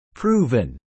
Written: proven

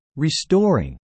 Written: restoring

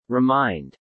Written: remind

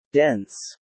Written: dense

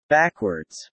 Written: backwards

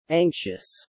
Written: anxious

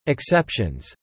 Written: exceptions

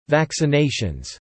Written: vaccinations

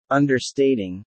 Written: understating

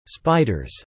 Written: spiders

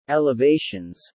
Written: elevations

